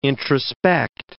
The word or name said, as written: introspect